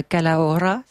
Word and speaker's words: Calahorra: Dans un château, le château de la Calahorra, c'est ça? – Oui.